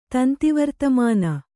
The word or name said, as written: ತಂತಿವರ್ತಮಾನ